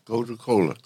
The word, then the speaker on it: Gotu Kola: Did I pronounce that right? – Yeah, Gotu Kola. – So G O T U K. No, it's two words. G O T U. Go to. Go to.